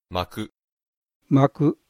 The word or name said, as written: maku